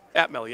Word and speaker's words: Atmel: And Atmel Corporation. Am I pronouncing that correctly? – Atmel, yes.